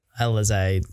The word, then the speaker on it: Alizé: How's the almost the awareness from like in the moment for Eliza, sorry, I didn't know how you pronounce Alizé. – Her name. Alizé to actually say that in the moment.